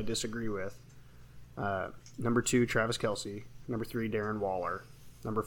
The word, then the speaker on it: Kelce: His last name is actually not pronounced Kelce.